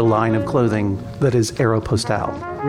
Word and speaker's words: Aeropostale: Aeropostale. – The 12-year-old claims that that word is pronounced Air Apostle, whereas Chang prefers the French pronunciation, which you just gave us.